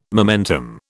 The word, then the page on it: momentum: British English